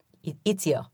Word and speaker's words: Ezio: Ezio? – Ezio.